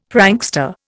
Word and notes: prankster: Indian

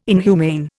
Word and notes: inhumane: Indian

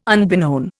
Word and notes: unbeknown: Indian

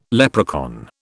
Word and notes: leprechaun: British English

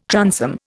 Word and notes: transom: Indian

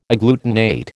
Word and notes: agglutinate: American English

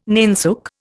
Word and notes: nainsook: Indian

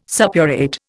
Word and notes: suppurate: Indian

